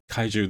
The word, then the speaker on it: kaiju: Kaiju, that's how you say it, right? – Yeah.